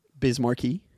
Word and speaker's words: Biz Markie: Biz Markie.